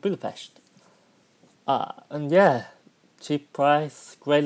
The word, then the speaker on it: Budapest: Now, some people pronounce it Budapest, which is actually the correct English version. However, the locals call it Budapest.